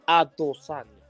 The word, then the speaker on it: Adesanya: Adesanya. – I know you're on the right track. It is a tough name to pronounce. Adesanya.